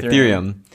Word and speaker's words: Ethereum: In the last 6 months, there's been a lot of talk about this thing called Ethereum. Am I saying that right? – Yes, you are. – Okay, Ethereum.